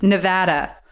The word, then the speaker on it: Nevada: Better than Nevada. Nevada. People get mad at me. I'm not good at pronouncing many things, but it's Nevada.